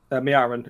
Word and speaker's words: Miaren: But the destruction of the Vallorn heart and that you described previously in, I'm terrible pronouncing this, the name is the region's Miren? – Miaren. – Miaren, that's it.